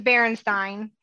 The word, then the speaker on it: Berenstain: It's Berenstain, it's not Berenstain. – Oh, that's what it is. But we always thought it was the Berenstain Bears. Yeah. Because it rhymes with bear.